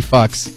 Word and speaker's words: Fuchs: Yeah, and the name, get ready for this, Guinea Fucks. All right, Fuchs. F-U-C-H-S. – Fuck you. – Like you pronounce that.